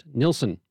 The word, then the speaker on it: Nilsson: whose name is pronounced Nilsson.